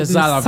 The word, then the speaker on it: Salovey: Is that how to pronounce it? Salovey. – Salovey. – Yeah.